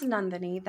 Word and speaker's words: Nandini: It's pronounced Nandini. That's the OG Indian way of saying it.